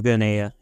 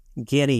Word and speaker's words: Guinea: Guinea, yeah. Well, yeah, guinea pigs.